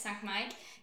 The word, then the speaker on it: Sankt Mike: I pronounce that right? – Yeah, you pronounce it perfectly right. It's Sankt Mike.